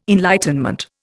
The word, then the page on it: enlightenment: Indian